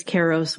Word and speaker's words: Kairos: So, and I'm going to pronounce Kairos as Kairos, which is like more the modern Greek pronunciation.